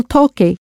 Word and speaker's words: toque: And since Spanish is written as it's pronounced, you spell toque, T-O-Q-U-E, in order to maintain that hard C sound, toque, T-O-Q-U-E.